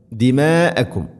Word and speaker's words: ദിമാഅകും: ലാ തസ്ഫികൂന ദിമാഅകും, ദിമാഅകും മദ് മുത്തസിലാണ്, നിർബന്ധമായിട്ടും നാലോ അഞ്ചോ ഹർക്കത്തിൻ്റെ ഖദറുകൾ നീട്ടണം.